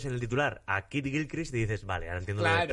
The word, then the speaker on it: Gilchrist: se han dado cuenta que a lo que aspiran es al puto Kid Grislich. – Kid Gilchrist. Gilchrist.